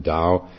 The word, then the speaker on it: Tao: In practicing the Tao for beginners is spelled T-A-O, is pronounced as though a D, D-A-O.